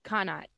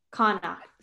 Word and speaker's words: Connacht: Am I saying that right? – Connacht. – Connacht. – C-O-N-N-A-C-H-T? – Yes. Yes, ma'am.